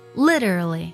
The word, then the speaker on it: literally: 首先呢，在读的时候，字母 T 呢可以做美式浊化，读作 litter，这是前两个音节，后面的 RA 呢读作 r，然后连起来呢是 litter，后面呢是 LLY 读作 li，连起来就是 literally。